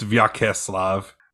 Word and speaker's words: Vyacheslav: It's Vyacheslav. – Well, I didn't say that. I said Vyacheslav. – You can pronounce it either way.